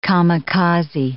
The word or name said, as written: kamikaze